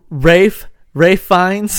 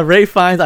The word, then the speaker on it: Ralph Fiennes: Ralph Fiennes. – That's it. You got it. – There we go. Ralph Fiennes.